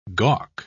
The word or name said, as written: gawk